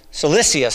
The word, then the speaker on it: Cilicia: I always mispronounce that there, all right. Cilicia, I believe is the right pronunciation, Cilicia.